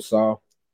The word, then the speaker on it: Saleh: Coach Saleh.